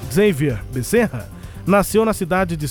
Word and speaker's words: Xavier: Xavier Becerra que é Xavier aqui no bom português, né? Lá no inglês eles falam Xavier, porque o X lá se pronuncia de um jeito diferente. Pra gente é Xavier Becerra.